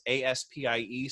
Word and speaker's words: Aspie: But it to for those watching, the space A S P I E space world. Um, it's pronounced Aspie, and uh, he it's an excellent resource.